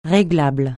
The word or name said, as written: réglable